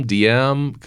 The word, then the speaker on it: Diem: Denim, Diem. It's Carpe Diem. I get that. But yeah, it gets mixed up. – Ryan. – It's the German pronunciation. I-E is a long E, right?